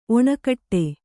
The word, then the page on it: ಒನಗೂಡು: onagūḍu; Kannada